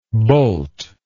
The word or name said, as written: boult